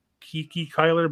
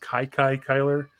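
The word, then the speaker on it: Kiki Cuyler: The oldest one for Brooklyn was, I always see this name as Kiki Kyler, but it's actually pronounced Kai Kai Kyler.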